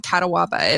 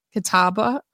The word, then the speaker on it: Catawba: So I read the back and I don't know what Catawaba is, but it's spelled Catawba. Catawba.